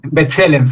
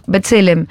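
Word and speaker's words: B'Tselem: Bueno, vos además colaborás con la ONG Betlehem, ¿cómo la pronuncio? – Betzelem se llama. – Betzelem, perfecto, Betzelem.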